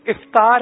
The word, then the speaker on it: افطار: افطار ہے، الف کے نیچے کسرہ یعنی زیر ہے، الف کے نیچے زیر ہے.